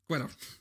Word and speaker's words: Quirrell: All right, is it Quill or Quirrell? How do you say his name? – Quirrell. – Quirrell. – Quirrell. – I thought it was Quill. – Quirrell, yeah. – Quirrell. – Quirrell. Oh, like a squirrel.